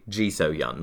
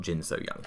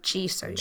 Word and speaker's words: Ji So-hyun: It's actually Ji So-hyun, not Jin So-hyun. – Oh, Ji So-hyun.